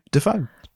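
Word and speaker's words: Dafoe: come in," and they think it was Dave Foe. That was his name, and he thought Defoe sounded better, so he switched to the other side of the family's pronunciation. And when he became an actor, he became Defoe.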